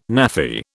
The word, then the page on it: NAAFI: British English